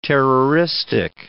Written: terroristic

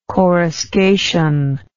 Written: coruscation

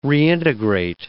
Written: reintegrate

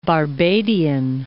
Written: Barbadian

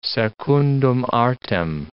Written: secundum artem

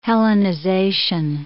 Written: hellenization

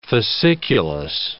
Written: fasciculus

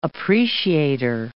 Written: appreciator

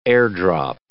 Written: airdrop

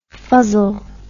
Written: fuzzle

